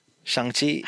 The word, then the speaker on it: Shang-Chi: Pronunciation, please. Shang-Chi? – Shang-Chi, yeah, okay.